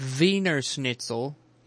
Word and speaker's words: Wiener Schnitzel: Wiener Schnitzel is how you say it, because I remember this. Germans say their W's like V's.